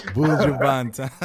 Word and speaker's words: Buju Banton: Banton, I'm sorry if I pronounced that wrong. – Buju Banton.